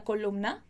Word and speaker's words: columna: Então, que nosotros leamos esta columna del periódico. Muita atenção aqui, olha aqui para mim. Muita atenção para essa pronúncia quando a gente tem M e N junto. Columna, columna. Columna, ok?